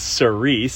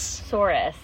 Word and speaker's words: Souris: And we thought it was Cerise. – Souris.